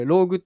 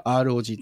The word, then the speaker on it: ROG: で、 ASUS まだまだね、いろんなのも出してて、スマホも今回ですね、 ASUS ブランドの、ゲーミングスマホ、 ROG Phone 8 海外発表、より薄く軽くなって IP68 対応、カメラも強化というところで、ASUS が ROG というゲームブランドらしいんですけど、これローグって読んじゃダメなんですよね。正式には ROG っていう、うん、エースは一個一個ね読むの難しいんですよね。